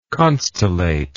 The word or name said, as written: constellate